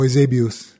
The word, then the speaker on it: Eusebius: and Eusebius, or Eusebius, as he would have pronounced it, the poetic, thoughtful dreamer.